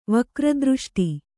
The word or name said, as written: ವಕ್ರದೃಷ್ಟಿ